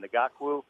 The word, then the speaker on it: Ngakoue: Ngakoue.